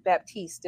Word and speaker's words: Baptiste: And today we have with us Tracey Baptiste. Did I say it correctly? – Baptiste. The P Baptiste. Is silent.